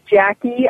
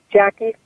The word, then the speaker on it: Jacqui: People can just type my name into a search on your computer, and my name is Jackie. I spell that J-A-C-Q-U-I, Jackie Thurlow Lippisch, J-A-C-Q-U-I-T-H-U-R-L-O-W-L-I-P-P-I-S-C-H. Boy, that's a mouthful. So it's Jackie Thurlow Lippisch.